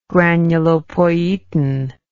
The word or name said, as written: granulopoietin